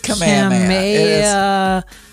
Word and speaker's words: Kamehameha: That is not Kamehameha. That is... – It is Kamehameha.